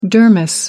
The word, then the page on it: dermis: American English